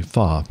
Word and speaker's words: pho: Actually, pho, everybody says, and including myself. Pho. But then I learned that it's actually pho. P-H-O is pronounced pho.